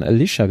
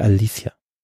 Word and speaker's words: Alicia: Übrigens, Alicia Silverstone, du hast vorhin Alicia gesagt. – Ach so, äh, da ja, hat sie sich vor ein paar Jahren mal drüber beschwert, dass sie seit Jahrzehnten äh, falsch ausgesprochen wird. Also sie heißt tatsächlich Alicia. So spricht sie.